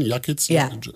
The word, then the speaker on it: Jakitz: Jetzt im Vorwort schreibt Madeleine Jakitz, wird sie ausgesprochen? Jakitz? – Ja.